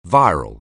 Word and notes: viral: anglais

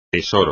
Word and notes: tesoro: espagnol

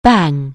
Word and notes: bang: anglais

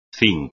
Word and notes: cinc: espagnol

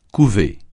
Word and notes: couver: French

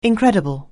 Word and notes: incredible: anglais

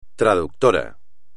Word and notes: traductor: espagnol

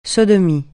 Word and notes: sodomie: francais

